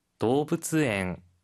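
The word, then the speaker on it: 動物園: 도브츠엥 도브츠엥 도브츠엥입니다. 도브츠 동물이고요. 뒤에 원은 엥이라고 읽어요. 그래서 동물원은 도브츠엥인데요.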